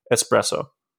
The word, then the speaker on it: espresso: the actually, the reason the reason that we say S in English for this is because we're using the Italian pronunciation espresso.